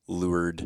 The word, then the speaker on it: Lourdes: Just pretend there's no S." – Thank you. – It's a silent S. It would be, I guess, if you want to go Webster Dictionary on it, would be like L-E-W-A-R-D, like Lourdes. – Lourdes. – No S there.